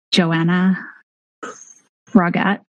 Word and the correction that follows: Joanne Froggatt: It's not Anna. It's Joanna or Joanne Froggatt. But it's not Fogarty.